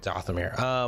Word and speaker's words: Dathomir: Like maybe on Dathomir? Am I saying that right? Dathomir? – Yeah, Dathomir.